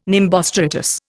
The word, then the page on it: nimbostratus: Indian